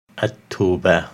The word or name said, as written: At-Taubah